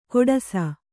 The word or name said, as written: ಕೊಡಸ